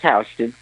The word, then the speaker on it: Houston: I was walking down Houston. – And it's called pronounced Houston? – No, it's Houston.